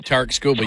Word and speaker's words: Skubal: I can't remember how you say his name, the guy from Detroit. – Tarik Skubal, yeah. – Skubal, yeah. Tarik Skubal.